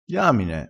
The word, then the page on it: diamine: dialettale